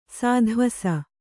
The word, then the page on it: ಸೋತೆವೆಸ: sōteves